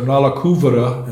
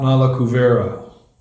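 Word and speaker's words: Nalakuvara: So, Kuvera Majo, the two sons of Kuvera, whose names are Nala Kuvara and Manigriva, please note it's not Nala Kuvera, it's Nala Kuvara, and a long U, Nala Kuvara.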